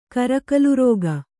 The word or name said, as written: ಕರಕಲುರೋಗ